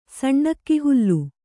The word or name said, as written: ಸಣ್ಣಕ್ಕಿಹುಲ್ಲು